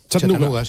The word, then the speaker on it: Chattanooga: ¿Y se pronuncia así en Chattanooga? – Chattanooga. Sí.